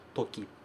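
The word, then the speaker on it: toki (時): Tempo, toki.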